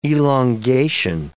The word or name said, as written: elongation